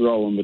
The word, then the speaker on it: Roland: It's pronounced Roland, but that's okay. – Roland. Okay. – There you go. – All right. Is that the most common way to pronounce it, Roland? – It's kind of spelled wrong. It probably should have been spelled R-A-W-L. – Yeah. – But it was a family name spelled R-O-L, so I got what I got.